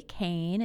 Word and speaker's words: Cain: My Twitter name is underscore Holly Cain, and Cain is spelled C A I N.